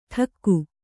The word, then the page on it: ಡಕ್ಕು: ḍakku